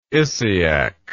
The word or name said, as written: Isiac